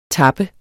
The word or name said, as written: tapper